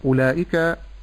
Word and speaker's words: أولئك: نقول وبالله التوفيق، الهمزة في كلمة أولئك مرققة فأقول أو، ونلاحظ هنا أن هناك واوا بعد الهمزة عليها صفر مستدير، فهذه لا تنطق. – نعم. – معنى هذا أن هذا الحرف لا ينطق.